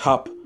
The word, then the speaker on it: hab: Now, if you wanted to shorten that, again, like I said, instead of saying habe, H-A-B-E, most times in German conversational, you'll hear hop, H-A-B, hop. And the B sound changes to a P. Ich hab die Grippe.